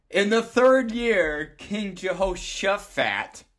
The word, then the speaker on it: Jehoshaphat: Well, you said Suffat, and it's actually Shuffat as it's written. – Oh, for crying out loud. – Right? – Jehoshaphat.